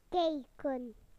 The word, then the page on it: to look: British English